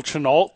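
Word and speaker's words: Chennault: I only know General Chenault because I go to that one Target and I don't even know how to pronounce it. – I think you just nailed it.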